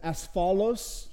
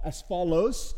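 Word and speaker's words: asphalos (ἀσφαλῶς): The best pronunciation I can come up with is asphalos, like asphalt, asphalos.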